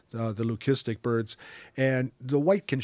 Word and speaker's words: leucistic: This, again, is that leucistic or sometimes pronounced leukistic.